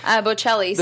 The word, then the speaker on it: Bocelli: The other two sort of auxiliary performances we got were this father-son duet on the pianos, the Botticellis, is that right? – Am I pronouncing that right? – Bocelli. – The Bocellis, yes.